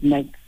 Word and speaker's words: Mx: Most people pronounce it MX, as in like, different you do when you're busy baking and cake, and you're combining the ingredients.